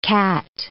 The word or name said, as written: cat